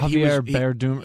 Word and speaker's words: Javier Bardem: I called it Javier Bardem. Am I saying his name right? – Javier Bardem.